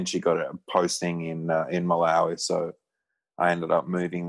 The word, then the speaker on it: Malawi: Malawi.